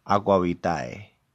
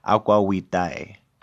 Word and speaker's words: aqua vitae: And the reason why they're calling alcohol water is because in Latin, the phraseology for alcohol, for distilled alcohol, was aqua vitae, or aqua vitae, because you pronounce the V as a W in Latin.